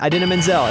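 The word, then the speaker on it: Idina Menzel: Adina Menzel is correct.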